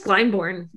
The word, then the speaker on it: Glyndebourne: First of all, I found, apparently back in 2014, a critic I never say this right, Glyndebourne. – I don't think I could do any better. – Glyndebourne. – Is it? – I think it's just Glyndebourne.